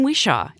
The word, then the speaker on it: Whishaw: Ben Whishaw.